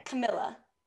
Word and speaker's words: Camilla: Camilla.